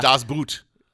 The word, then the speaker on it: Das Boot: And I thought it was Das Boot. – I'm not sure. I think they say boat. I think it's pronounced boat in German, but even though it's spelled boot.